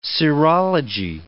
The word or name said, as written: serology